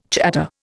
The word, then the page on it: chatter: Indian